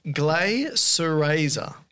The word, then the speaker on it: Glycyrrhiza: It's pronounced Glycyrrhiza.